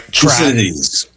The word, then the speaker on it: Thucydides: Thucydides.